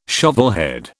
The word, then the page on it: shovelhead: British English